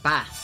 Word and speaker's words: Bath: Now, if I was from Yorkshire, I'd pronounce that Bath. That's how they pronounce it in Yorkshire, Bath.